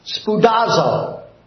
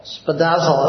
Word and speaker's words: spoudazo: It is the Greek word spoudazo. When I first heard it pronunciated that way, it didn't sound like Greek to me at all. Spoudazo, it almost sounds Japanese. Spoudazo, but it's really Greek.